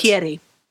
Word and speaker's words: Muschietti: Muschietti.